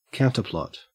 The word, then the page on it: counterplot: Australian English